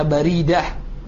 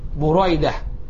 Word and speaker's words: Buraidah: Buraidah bisa dibaca Baridah. Akan tetapi, dengan penjelasan para ulama, bidomi awwalihi wa fathi tsani, dengan didomahkan huruf pertamanya, kemudian difathahkan huruf keduanya. Jadi bacanya jelas. Buraidah, bukan Baridah. Ya. Tapi Buraidah.